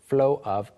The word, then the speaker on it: flow: 발음상으로 flow of 이게 약간 floor처럼도 들릴 수 있는데 주의해 주시고 flow of everyone else.